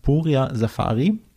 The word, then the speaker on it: Puria Safary: Und zwar ist heute zu Gast Puria Safari.